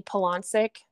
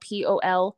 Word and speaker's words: Poloncic: So I just have Facebook and Instagram, and both of them are just my name, Maggie Poloncic. The last name is spelled Poloncic.